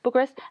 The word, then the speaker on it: Bucharest: I can pronounce Dubai, Kuala Lumpur, Chiang Mai, which is where you are, Singapore, Budapest, Frankfurt, Bucharest, is it? – Yep. – Yeah, Bucharest.